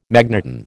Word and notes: magneton: American English

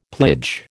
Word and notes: plage: American English

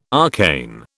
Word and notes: arcane: British English

